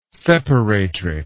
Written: separatrix

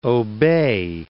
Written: obey

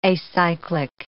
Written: acyclic

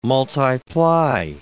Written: multi-ply

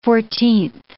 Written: fourteenth

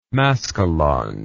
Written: maskalonge

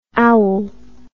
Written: aoul

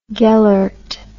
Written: gellert

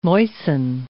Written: moisten

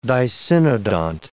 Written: dicynodont

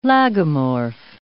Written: lagomorph

